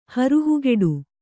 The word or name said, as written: ಹರಹುಗೆಡು